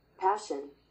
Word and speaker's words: passion: पैसन क्या अबे ये पैसन बोलते कैसे प्रोनाउंसिएशन क्या इसका पैशन पैशन वापस पैशन passion passion passion passion passion okay passion जो